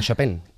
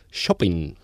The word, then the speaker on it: Chopin: Bueno, esto era la marcha fúnebre de Chopin, que no Chopin, este pianista y compositor es polaco, no es francés. Se fue después a Francia. Entonces en Polonia lo pronuncian Chopin.